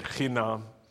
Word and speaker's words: chinam: But I asked this question, how do you say free in Hebrew? As a 10-year-old, and she said, chinam.